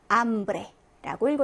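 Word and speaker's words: hambre: 자, 아체가 이제 처음 스페인어를 접하는 학습자들은 많이 실수를 하는데, 이제 영어의 H다 보니까 히읗 발음이 날 거라고 생각하지만, 스페인어에서는 묵음입니다. 다시 말해서 발음되지 않습니다. 있지만 발음하지 않아요. 그러면 어떻게 읽으시면 될까요? 맞아요. 암브레라고 읽어주시면 되고, 에르모소라고 읽어주시면 됩니다. 그래서 아체는 묵음이다라고 생각하시고요.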